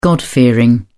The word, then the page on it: god-fearing: British English